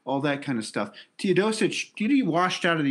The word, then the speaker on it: Teodosic: Um, what's a little scary is that Teodosic,